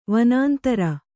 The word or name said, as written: ವನಾಂತರ